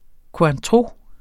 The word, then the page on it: cointreau: kwɑ̃ˈtʁo